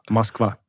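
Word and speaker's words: Moskva (Москва): Moskva.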